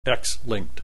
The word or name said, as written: X linked